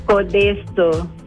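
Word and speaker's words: Codeço: Codeso. Codeso, tiene esa C con ese palito hacia abajo que, es del portugués que nosotros no ocupamos en el español, por eso que, eh.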